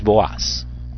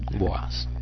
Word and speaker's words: Boaz: Vos. Realmente la pronunciación es Boaz. Boaz.